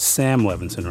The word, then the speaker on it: Sam Levinson: I want to make sure I'm pronouncing the name right. It's Sam Levinson, right? – Correct, yeah.